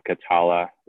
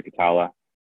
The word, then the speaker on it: Catalla: Facebook, just search my first and last name, Andrew Catala. Last name is spelled C-A-T-A-L-L-A. Same with Instagram, Andrew Catala.